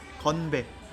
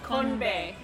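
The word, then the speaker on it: Konbae (건배): How do you say cheers in Korean? – Konbae. – Konbe. – Yeah. – Like kanpai, yeah, or ganbe. Very similar.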